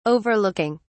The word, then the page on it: overlooking: British English